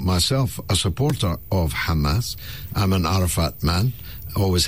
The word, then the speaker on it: Hamas: All right, so what about the terrorist Hamas? Or maybe I should pronounce it better. Hamas.